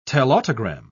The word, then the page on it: telautogram: British English